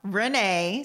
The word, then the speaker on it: Renae: My name is Renee, and my Instagram handle is at I am Renee, spelled R-E-N-A-E, with an A. Because most people don't spell it that way. R-E-N-A-E.